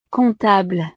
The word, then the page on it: comptable: frances